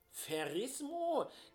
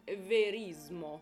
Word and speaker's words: Verismo: nämlich dem Ferismo. Verrismo? Geht es da um Verrisse von Opern in den Zeitungen damals? Nein, ich habe das natürlich ganz falsch ausgesprochen. Verismo.